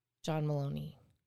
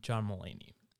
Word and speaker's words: Mulaney: And John Mulaney. – It's John Mulaney. – I said it right the second time.